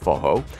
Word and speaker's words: Forró: That's spelled F-O-R-R-O, but pronounced Forró.